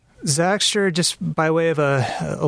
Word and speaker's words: Zagster: So Zagster is, that's how you say it, right? – Mm-hmm.